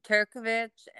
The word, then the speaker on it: Turkovich: Is that correct? Is that how you pronounce it? – Correct. And okay. My Facebook goes under Turkovich, and Clendenin was my maiden name, but I have it open to the public.